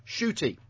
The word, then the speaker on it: Ncuti: Butch has corrected us and said Ncuti, as it's written down phonetically, is pronounced shooty.